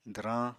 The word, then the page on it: dwrañ: apatani